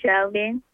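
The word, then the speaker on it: Shalbyen: My name is Shelvin.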